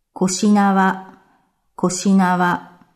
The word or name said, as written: Koshinawa